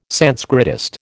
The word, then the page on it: Sanskritist: American English